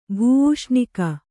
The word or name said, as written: ಭೂಔಷ್ಣಿಕ